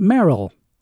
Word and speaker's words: Mayerl: His last name is spelled M-A-Y-E-R-L, but pronounced Merrill.